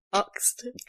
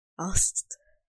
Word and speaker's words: asked: How do you normally say it? – I don't know. Oxed. I say ox. And he always gets at me. – That's such bad vibes. – Asked. – Is it pronounced A-K-S-E-D or A-S-K-E-D? – Well, I know how it's spelt, thank you very much. Okay. – Pronounce it then. – Asked.